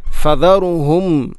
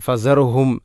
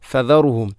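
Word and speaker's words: ഫദറുഹും (فَذَرْهُمْ): ഇവിടെ ഫദറുഹും ഫദറുഹും ഫസ അല്ല ഫദ. ഇവിടെ ദാലിന് പലപ്പോഴും നമ്മൾ ഉച്ചാരണത്തിൽ ഫസറുഹും സായോട് സാമ്യമുള്ള ഒരു ശബ്ദമാണ് പലപ്പോഴും നമ്മളിൽ നിന്നും കേൾക്കാറുള്ളത്. ആ ഭാഗം ശ്രദ്ധിക്കണം. ഫദറുഹും.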